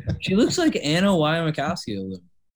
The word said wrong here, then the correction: Wasikowska: Did I say her name right? – Anna Mia Wasikowska. – Anna Mia Wasikowska, yeah.